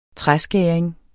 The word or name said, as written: træskæring